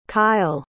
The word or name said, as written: kyl